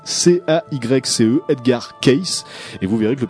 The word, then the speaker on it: Edgar Cayce: aujourd'hui, il y a un mythe autour de ce Edgar Case.